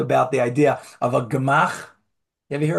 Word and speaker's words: gemach: It's spelled in English, Gemach, gemach, but it's not a real word. It's an acronym. It stands for Gemilut Chasadim. It's an acronym, Gimel Mem Chet, gemach, but it's not a real word.